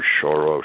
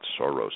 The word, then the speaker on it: Soros: and George Soros specifically. And by the way, that happens to be the correct Hungarian pronunciation. It's not Soros as we pronounce it in the U.S.